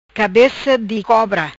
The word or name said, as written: Cabeca di cobra